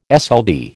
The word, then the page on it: SLD: American English